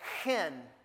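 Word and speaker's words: chen (חֵן): It's chanun or chen, and you say it like you say hen, but it's got a K on it, so it doesn't really have a K on it, but that's the way we have, like, need to pronounce it, chen, right? You almost have to clear your throat when you say it, chen.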